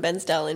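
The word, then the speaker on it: Bairnsdale: I am from Bensdale in Victoria. – Barnsdale. – Not mate, you're saying it wrong. – No. Say it like the locals do.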